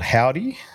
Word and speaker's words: Hovvdy: I've been listening to a lot of this band called Howdy from the States, which is, it's spelt H-O-V-V-D-Y.